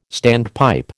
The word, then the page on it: standpipe: American English